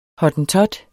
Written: hottentot

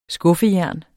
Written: skuffejern

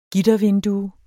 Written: gittervindue

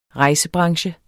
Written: rejsebranche

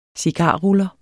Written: cigarruller

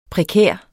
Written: prekær

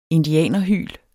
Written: indianerhyl

